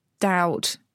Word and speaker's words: doubt: So doubt, be, they stuck that in because the Latin dubitum.